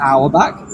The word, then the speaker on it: Auerbach: Auerbach, which still trying to pronounce Auerbach. – Auerbach. – Yeah. – Auerbach. My back and your back.